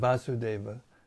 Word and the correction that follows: Vasudeva: Well, that's a mistake. There's no long A in Vasudeva. Make a note. Okay? When Krishna appeared as the son of Vasudeva in Devaki, he first of all appeared as four-armed Narayana.